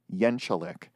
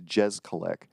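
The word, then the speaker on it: Jeczalik: So his first name is JJ, and when asked, he pronounces his last name Yenchalik. However, I've seen plenty of interviews where the interviewer pronounces it as it's spelled or how it looks, which is Jezkalik.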